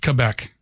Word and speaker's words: Quebec: And so I asked them about pronunciations of things, and they were insistent that their province is pronounced Quebec. – Really? – Yes.